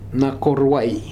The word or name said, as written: Nakoruai